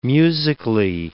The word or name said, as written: musically